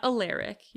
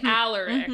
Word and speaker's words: Alaric: Well, and he also specifically was like, it's not Alaric. You're gonna wanna say Alaric. Mm-hmm, mm-hmm. Which is true. That's what I wanted to say. – Mm-hmm. – Alaric.